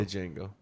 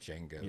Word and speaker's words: Django: The D is silent. – It's just Django.